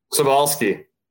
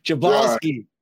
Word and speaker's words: Cebulski: Ryan Talbot, AJ Cebulski. Did I say it right? – Cebulski. – All right. – Now you know. – I'm horrible with last names. Cebulski.